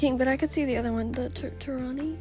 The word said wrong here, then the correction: Taraji: Is that how you say her name? – Taraji.